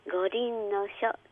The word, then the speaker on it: 五輪の書: 五輪の書です。 – 五輪の書。あ、蓋を開けたら風呂敷包がありますね。 – はい、で今度は漆塗りの箱、こちら開けたら、道子先生、これは？ – これは宮本武蔵が書いた五輪の書です。